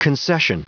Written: concession

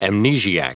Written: amnesiac

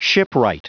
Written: shipwright

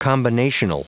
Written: combinational

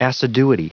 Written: assiduity

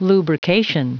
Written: lubrication

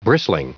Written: bristling

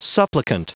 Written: supplicant

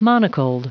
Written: monocled

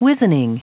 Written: wizening

